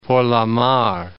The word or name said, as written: Porlamar